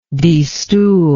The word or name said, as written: destoolment